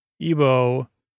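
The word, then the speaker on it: Igbo: McMansion, Rask of Flans, Artificial Nails, Good Museums in Budapest, Pedagogy, Interstate 15, Apology, Speaky Bank Stack, McStuff, and Yoko, Ebo, with, I believe, a silent G. It's I-G-B-O, language, Foyer, Mitterrand, Hearth, couple word offensive words in the UK, and yoga, restorative yoga.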